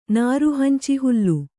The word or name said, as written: ನಾರುಹಂಚಿಹುಲ್ಲು